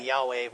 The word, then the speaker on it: Yahweh: And I'll be pronouncing that Yahweh, which is kind of the way people do nowadays. It was Jehovah at one point, and who knows what else, but Yahweh is how I'll be saying it.